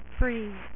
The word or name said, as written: mister freeze